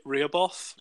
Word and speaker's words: Rehoboth: It's R-E-H-O-B-O-T-H. So Rehoboth? – What? – Beach? I don't know how to pronounce it. – I'm so confused. – No, you pronounce it right. Yeah. – Rehoboth Beach. Okay. That was better.